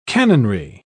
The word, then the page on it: cannonry: British English